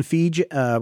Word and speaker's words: Feige: So Kevin Feige, what did we say his name was? Feige. Rhymes with Pygy. That's how I remember.